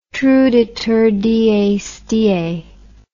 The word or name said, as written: truditurdiesdie